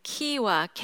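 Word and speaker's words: KYWA: 키와 K Y W A라고 합니다. 그 발음을 같이 해보실까요? 좀 키와 키와 키와 아이들을 키운다 그런 느낌이 드시죠?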